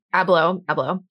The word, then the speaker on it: Abloh: Did I say his name right? I never know. – Abloh.